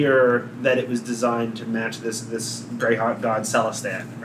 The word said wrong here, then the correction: Celestian: Or however it's pronounced. – Celestian.